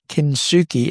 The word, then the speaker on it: Kintsugi: including Kintsugi.